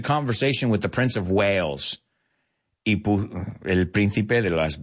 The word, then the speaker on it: Wales: So he's from Wales. Que suena igual que tiburones, ballenas. Pero sin la H se escribe. Pero se pronuncian igual.